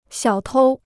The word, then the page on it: 小偷: xiǎo tōu